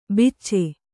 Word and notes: ಬಿಚ್ಚೆ: Kannada

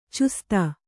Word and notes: ಚೌಸುತ್ತು: causuttu

